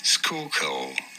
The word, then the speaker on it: Schuylkill: How do you go about pronouncing it? Schuylkill. – Schuylkill. – Schuylkill. – Schuylkill. – Okay. – Good. I was way off. It's like the SCH, like school.